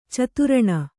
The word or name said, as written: ಚತುರಣ